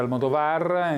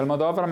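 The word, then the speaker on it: Almodóvar: Sì, in effetti c'è chi lo pronuncia Almodovar, in realtà si pronuncia Almodovar, ma addirittura lui rinuncia anche al suo nome di battesimo, perché è diventato quasi un marchio.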